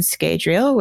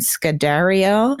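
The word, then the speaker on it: Scadrial: And they discuss some of Aelai's findings, like her notebook where it mentions Nalthus and Skadriel, which he corrects her Rosharan pronunciation, Nalathus and Skadariel.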